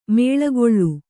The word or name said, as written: ಮುಳ್ಳುಗಳ್ಳಿ